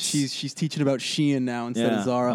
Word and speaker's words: Shein: Sheen or whatever the how do you know how to Shein? – Pronounce it? – Shein.